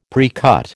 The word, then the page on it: precut: American English